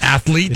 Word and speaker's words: athletes: Athletes? – It's athletes. Yeah, you don't really pronounce that A. It's athletes. It's athletes.